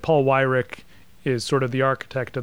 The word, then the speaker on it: Weyrich: I think his last name is pronounced Weyrich. – Oh, Weyrich. Okay.